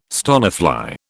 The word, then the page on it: stonefly: British English